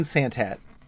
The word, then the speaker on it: Santat: Is it Santat? Is that how you pronounce it? It's as easy as it seems. Dan Santat.